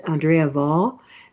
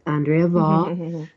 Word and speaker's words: Andrea Vahl: And her name is Andrea Vahl, V-A-H-L. So the ill is silent, but Andrea Vahl.